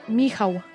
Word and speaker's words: Michał: How did you say his name again? – Michał. – Michael, yeah. – Oh, that's right. Michał.